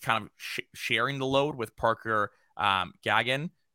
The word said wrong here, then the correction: Gahagan: Is that how you say it? Gahagan. Gahagan. Yeah. H was silent.